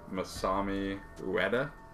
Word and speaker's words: Masami Ueda: So I really kind of admire the composer Masami Ueda.